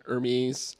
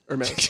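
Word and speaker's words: Hermès: There's rumors that there's different Hermes colors. Hermes. Thank you.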